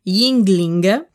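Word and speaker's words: Yuengling: Yingling.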